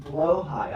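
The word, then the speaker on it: Blåhaj: But it's, in Swedish, pretty sure, in Swedish it's pronounced Blowhaj.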